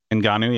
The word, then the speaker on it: Ngannou: When I saw Ngannou, is that how you say it? – Ngannou, yeah.